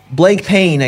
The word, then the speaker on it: Blancpain: They're doing a bunch of these Blancpain-style watches Is that how you say it in French? Blancpain? – Yeah, or blank pain, I guess, if you're American.